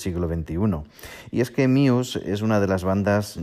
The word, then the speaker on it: Muse: Eh, el título de este podcast es Let's Talk About Muse o Muse, si lo leyéramos literalmente o lo pronunciáramos literalmente en español. Pero vamos a nombrarlo Muse, que suena muchísimo mejor, si os parece.